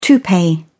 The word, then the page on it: toupee: British English